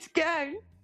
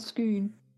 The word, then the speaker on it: Scone: Do they pronounce it stone, scone or scoon? – Scone. – Or scone. I did read something, but I haven't written it down. It could be the Stone of Scone, scoon or scone.